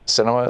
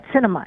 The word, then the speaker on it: Sinema: Sinema is her name. Yeah. – That's right, Sinema. It's pronounced like the cinema.